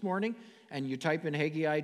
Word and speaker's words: Haggai: Everybody say Haggai. Haggai. Some pronounce it a little bit different. Haggai.